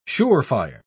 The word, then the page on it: sure-fire: British English